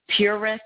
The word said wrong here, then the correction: Pu'er: or Purit tea. – Yeah, Pu'er.